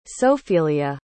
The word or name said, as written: Sophelia